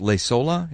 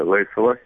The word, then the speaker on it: Leisola: Okay, so his name is Dr. Matti, which is spelled like this, M-A-T-T-I. The last name is pronounced Leisola, is that right? – Yeah, Leisola. – Leisola. Okay, and it's spelled L-E-I-S-O-L-A.